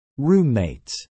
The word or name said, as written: roommates